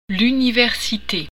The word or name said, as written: université